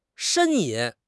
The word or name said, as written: 呻吟